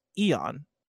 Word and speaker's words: Aeon: Nana Biologist says Aeon is pronounced Eon.